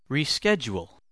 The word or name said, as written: reschedule